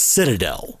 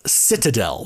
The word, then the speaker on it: citadel: Citadel. – It's a C-I-T-A-D-E-L. I had thought it was actually pronounced citadel, or you could actually say it either way. And apparently I was wrong about that.